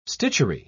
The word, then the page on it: stitchery: British English